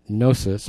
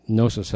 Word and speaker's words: gnosis: And later in the sentence, the word that surpasses knowledge, that's the word gnosis. Both have a silent, well, gnosis has a silent G, G-N-O-S-I-S.